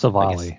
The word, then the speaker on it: Civale: Are we called Civale? Is that how you say it? Civil Civale? – I think it's Civale.